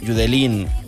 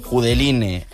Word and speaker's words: Judeline: Y bueno, la historia de por lo que estamos escuchando esto es que Judelín, Judeline, Judeline, como la queramos llamar, porque todos los nombres son válidos.